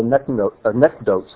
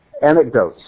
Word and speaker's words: anecdotes: And the rabbi who's commenting on it puts his own little anecdotes at the bottom. Anecdotes. Thank you. Anecdotes. First syllable.